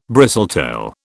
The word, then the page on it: bristletail: British English